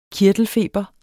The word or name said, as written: kirtelfeber